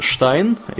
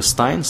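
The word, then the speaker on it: Stein: И кто-то голосовал действительно из них за Трампа, кто, а кто-то голосовал, например, за э, кандидата от партии зеленых, э, Джилс Штайн, или Стайн, Стайн, по-моему, ее фамилия э, правильно произносится, и у которой программа, на самом деле, была во многом похожа на программу Сандерса.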